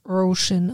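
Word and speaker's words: Róisín: Roisin. Ist ein irischer Frauenname. Und wird Roisin ausgesprochen. Roisin, siehst du.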